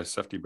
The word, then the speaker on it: Safdie: The Safdie Brothers? Is that how you pronounce that? – Yeah, Safdie Brothers. I think that's right.